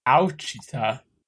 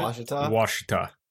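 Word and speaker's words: Ouachita: It's called the Wichita National Forest, or the Ouchita. – Washita.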